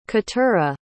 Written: keturah

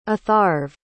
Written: atharv

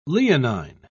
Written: leonine

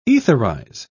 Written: etherize

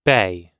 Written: bae

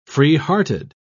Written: freehearted

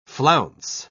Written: flounce

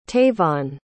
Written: tavon

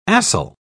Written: acyl

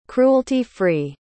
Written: cruelty-free

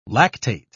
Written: lactate

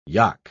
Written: yogh

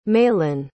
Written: malan

